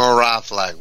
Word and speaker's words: Mravlag: It's M-R-A-V like Victor L-A-G like George. – Fantastic. – Victor Mravlag, one syllable.